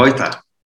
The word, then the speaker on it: Reuter: And, you know, like at the end of, like, if you have ER at the end of a German word, it's pronounced A. So it's Reuter. Just, ah, know. Reuter.